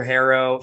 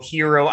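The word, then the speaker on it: Herro: Um, but one thing that I don't think we talk about enough is the fact that Tyler Harrow, Hero, I've heard it pronounced both ways, whatever.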